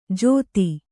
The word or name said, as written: ಜೋತಿ